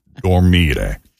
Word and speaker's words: dormire: Voglio dormire. – Dormire. – I want sleep. Yeah, so it's spelled D-O-R-M-I-R-E.